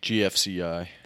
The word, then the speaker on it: GFCI: Oh, and I changed out a GCFI. I always say it wrong. – GFCI. – Fuck. Okay, so my instinct said GFCI, but I always say it wrong. So I went with the double faint with the GCFI, and I was wrong.